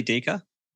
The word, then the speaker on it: Diecke: It's technically Diecke.